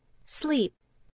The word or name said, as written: sleep